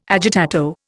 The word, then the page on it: agitato: Indian